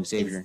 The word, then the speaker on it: Xavier: Oh, Xavier. – It's Xavier. – Xavier? – Xavier. You don't pronounce the X as a Yugs.